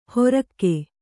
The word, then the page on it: ಹೊರಕ್ಕೆ: horakke